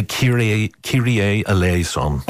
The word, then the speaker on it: Kyrie Eleison: What we have, and I'm not sure I'm pronouncing this properly, from Bach's B Minor Mass, Kyrie Ellison, performed by the Monteverdi Choir. – The Kyrie Eleison. – Oh, there you go. I got that completely wrong.